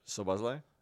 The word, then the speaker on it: Szoboszlai: Szoboszlai? – Yeah.